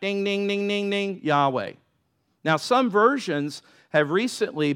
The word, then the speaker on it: Yahweh: Now, some traditions have called it Je- the name of Jehovah, same thing, but, but Hebrew scholars would, would tell you that the J, pronouncing it Jehovah, is not proper, but it's Yahweh.